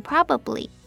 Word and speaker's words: probably: 那第二个字 probably 它的重音在第一音节，第二个音节的母音 a 我们要发弱化母音 a 的声音，所以念成 probably。